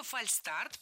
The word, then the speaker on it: фальстарт: Слово «фальстарт» пришло к нам из английского языка «fail start», то есть ошибочный старт. Никакого звука «ш» здесь нет.